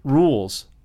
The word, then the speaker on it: Rhule: Rhule's. Is that how you say his name? – Rhule.